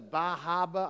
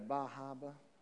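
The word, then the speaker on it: Bar Harbor: and then we went to Bahaba. I learned that's how they say it up there, Bahaba.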